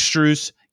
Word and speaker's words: Strus: Max Struess, I just said his last name wrong. Max Strus, Gabe Vincent, Duncan Robinson, Haywood Highsmith, and Caleb Martin, all undrafted.